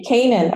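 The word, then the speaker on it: Kanen: That's K-A-N-E-N. – But it's pronounced Kanan.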